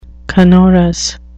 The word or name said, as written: canorous